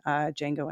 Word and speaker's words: Django: and he even made a small cameo in Django Unchained. He's in that part where Jamie Foxx is like saying his name, and then he's like, the D is silent.